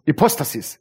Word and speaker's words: Hypostasis: Hypostasis. Habe ich es richtig ausgesprochen? Sag mir nochmal. Hypostasis? Hypostasis. Hypostasis. So spricht man es aus.